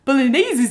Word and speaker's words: bolognese: Julie, please, also, you're not saying it right. Please say it correctly. It's bolognese is easy.